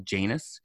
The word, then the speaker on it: Janus: Like he pronounces Janus as Janus, which is a very British way of pronouncing it.